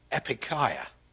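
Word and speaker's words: epikaia (ἐπιείκεια): But anyway, this word, which I am told is roughly pronounced epikaia, is translated here as gentleness.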